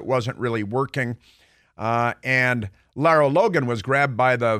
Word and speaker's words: Lara: It's L A R A, and and pronounced she's South African uh, originally, and and LARA Lara Logan, she goes by, and she has been a very serious reporter.